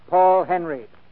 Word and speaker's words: Paul Henreid: He pronounces his name Paul Henreid.